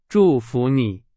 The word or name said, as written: 祝福